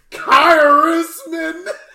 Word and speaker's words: Khaerisman: Bro, I hit you with the K-H-E-R-I-S-M-A-N Kairisman. You know what I'm saying? No, funny story, Kairisman's not a real last name.